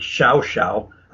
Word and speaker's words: Llao Llao: and it's spelled L-L-A-O, L-L-A-O, and it's pronounced down there as Xiao Xiao.